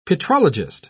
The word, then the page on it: petrologist: British English